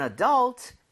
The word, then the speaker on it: adult: Alright, so one for an adult and one for a 12-year-old. Okay, 정답 맞습니다. – 오. – 성인이라는 단어, adult, right? – 네. – 자, 강세를요, U에다가 찍고 발음을 하면 좋습니다.